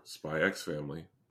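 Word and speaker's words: Spy × Family: Spy Family or Spy X Family. People don't pronounce the X, and I understand that.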